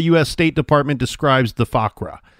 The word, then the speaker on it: Fuqra: Fakwa, And I'm not saying that right. F-U-Q-R-A, Fakra.